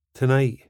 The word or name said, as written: tonight